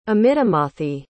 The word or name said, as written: amitamathi